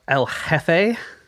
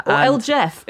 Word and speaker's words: El Jefe: He called himself El Jefe. – Mm-hmm. El Jeff, if you want to give it this anglicized pronunciation, which is more funny.